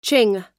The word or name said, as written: ching